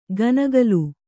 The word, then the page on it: ಗನಗಲು: ganagalu